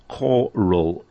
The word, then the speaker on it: choral: If you've just joined us, the word is pronounced choral, as from the root of chorus.